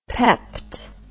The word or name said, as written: pepped